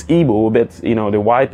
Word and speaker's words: Igbo: They couldn't pronounce it. They called Hebo.